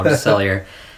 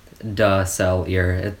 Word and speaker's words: DeSalier: It is French. It's pronounced more than American way now, DeSalier. DeSalier.